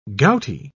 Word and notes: gouty: British English

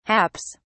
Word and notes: haps: British English

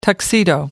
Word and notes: tuxedo: American English

